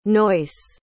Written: noyce

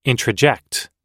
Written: introject

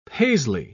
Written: paisley

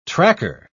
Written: tracker

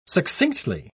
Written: succinctly

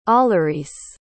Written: allaryce

